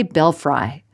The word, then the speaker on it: belfry: But it's spelled B-E-L-F-R-Y, and I always want to say belfry, but it's belfry.